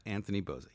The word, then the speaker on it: Anthony Boza: So I said it correctly? – Anthony Boza, yes. – Boza, right. I said it correctly because yeah. I know there's been some debate on how your name is said, but that is the correct pronunciation. – Mm-hmm. From the Italian.